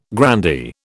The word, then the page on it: grandee: British English